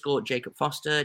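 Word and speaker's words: Jakob Forster: you can find me over at _ Jacob Foster, JAKOBFORSTER.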